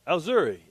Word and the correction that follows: Ujiri: Yeah, Ujiri.